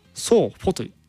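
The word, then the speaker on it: sofo: ちなみになんですけどもソファーはソーフォと発音しましょうねそこはちょっと合わせてほしかったなって感じなんですけど、まあ、ソーフォなんですね。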